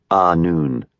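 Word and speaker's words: Annwn: "Why, to Annoon, my love," the Gilifartan said, pronouncing it Annoon.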